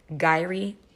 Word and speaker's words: gyri: Sulci, which means peaks, and don't quote me on this, but I believe it is pronounced gyri, which is valleys. Okay. Gyri, not like Kyrie Irving. Okay. But gyri, valleys.